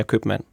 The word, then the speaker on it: købmand: så mange steder i landet hører man faktisk folk, der siger købmand. – Så det er et ord, der er gået fra et stumt B til at have et udtalt B, ja.